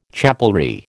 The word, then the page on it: chapelry: American English